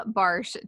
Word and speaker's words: Barosh: And you will notice that her last name is spelled B-A-R-O-S-H, but we have just confirmed that I have pronounced that right, even though it looks like it's wrong.